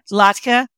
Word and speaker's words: latke: Oh, latke.